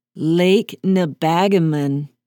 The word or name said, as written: lake nebagamon